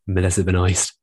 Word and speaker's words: Melissa Benoist: Melissa Benoist.